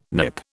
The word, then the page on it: Nip: British English